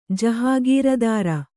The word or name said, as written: ಜಹಗೀರದಾರ